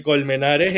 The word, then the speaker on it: Colmenares: El, el Colmenares es, es, eh, eh, es, cero, es cero así difícil de pronunciar.